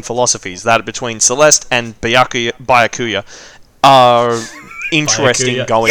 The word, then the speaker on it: Byakuya: So, Yakuya, and then just put a but at the beginning. But Byakuya.